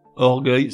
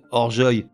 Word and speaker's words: orgueil: Je m'explique, avec une terminaison en EUIL, orgueil se prononcerait orgeuil » et cercueil donnerait cerceuil ».